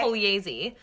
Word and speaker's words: Pugliese: Say the G is silent. It's okay. Pugliese.